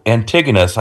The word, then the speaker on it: Antigonus: And who kills Antigonus? I hope I pronounced it right. A-N-T-I-G-O-N-U-S.